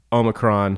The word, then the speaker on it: Omicron: Obviously, COVID, Omicron.